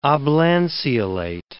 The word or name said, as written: oblanceolate